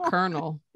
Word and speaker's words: colonel: There's a corn kernel, which has an R, but then there's a whole person in the army named Colonel, and there's not near R in that word.